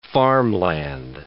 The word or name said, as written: farmland